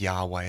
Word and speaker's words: Yahweh: Now, most probable way of pronouncing that, I mean, I think Yahweh is probably the best.